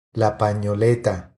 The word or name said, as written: La panoleta